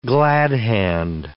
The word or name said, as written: glad-hand